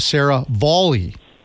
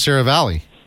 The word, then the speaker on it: Seravalli: I believe Frank's last name is pronounced Sarah Volley. Do you go by, I mean... Most of the times I've heard Sarah Valley.